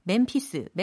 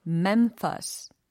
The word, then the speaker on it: Memphis: 어, 특히 이 제목이 Walking in M-E-M-P-H-I-S라고 해서 우리가 소위 멤피스, 멤피스 이렇게 발음 많이 하잖아요. 근데 사실은 어, walking in Memphis, Memphis 이렇게 들립니다.